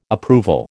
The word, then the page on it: approval: American English